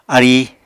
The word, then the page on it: aríi: Apatani